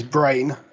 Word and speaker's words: brane: But yeah, the next one is Brain, but that's spelled B R A N E.